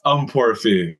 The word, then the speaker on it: Ukponrefe: Uh, it's Ukponrefe, if anyone wants to know.